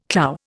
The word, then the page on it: clou: Indian